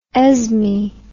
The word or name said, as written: Esme